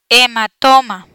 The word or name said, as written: hematoma